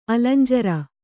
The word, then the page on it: ಅಲಂಜರ: alañjara; kannada